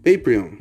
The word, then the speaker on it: Paprium: Patreon. Em inglês o pessoal pronuncia desse jeito, Patreon que se escreve aqui em português, se escreve Paprium.